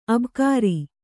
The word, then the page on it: ಅಬುಕಾರಿ: Kannada